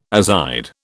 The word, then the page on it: azide: British English